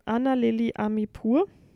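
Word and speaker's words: Ana Lily Amirpour: Ähm, Anna Lili Amipur. – 100% korrekt ausgesprochen, bestimmt, ja.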